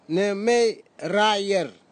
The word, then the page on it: nimeirair: Nafe; Tanna